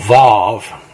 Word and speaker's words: Vav: Every verse that starts with a V, which is called Vav in Hebrew, and it's pronounced like vine, is linking each successive verse together with the flow of factual narrative statements.